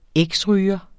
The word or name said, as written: eksryger